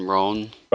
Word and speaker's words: Roan: It says, dubbed the Abomination because of his appearance, enslaved gladiator Roan, am I pronouncing that right, R-O-A-N, Roan? – Roan, yeah.